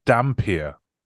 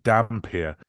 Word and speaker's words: Dhampir: Dampier. – What's that? – That's what he is, apparently. Dampier. It's like vampire without the E on the end, and instead of V, it's Dhampir.